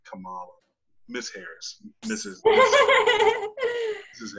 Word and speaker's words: Kamala: um, so moving forward yeah camila harris was chosen as biden's running mate um, kamala harris Kamala. I'm sorry, I've been saying that wrong. I thought it was Camila.